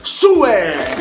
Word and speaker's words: Sue: Signori e signori, qui per MV Radio, Sue! – Ciao a tutti, soprattutto grazie di chiamarti Sue, letto come si scrive, perché finalmente è riuscito a pronunciare un nome correttamente.